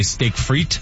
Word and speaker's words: steak frites: Did you know the French don't even pronounce the S? They just say steak frites.